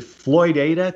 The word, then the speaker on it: Floydada: Is it Floydada, Texas? – That is correct. My Yankee tongue, I always want to say Floydada, but it's Floydada.